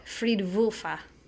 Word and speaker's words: Fridwulfa: Fridwulfa. – Yeah. When I saw the W, that's how I wanted to say it.